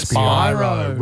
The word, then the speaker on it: Spyro: It's Spyro. Rhymes with Byro.